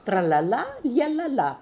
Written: Tralalaooalalalala

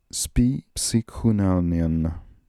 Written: spipsychoonalnian

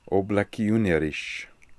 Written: Oblekioonerish

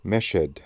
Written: Meshed

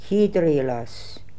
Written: Herailaz

